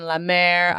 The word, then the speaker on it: La Mer: Like, she uses high-end beauty products like La Prairie and La Mer.